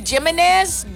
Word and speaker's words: Jiménez: So, y luego también, como les dije hace ratito, la J, pues como la el nombre Jiménez, aquí los maestros dicen Jiménez. Johnny Jiménez, Johnny Jiménez, y no es Jiménez, pero no con H, con J.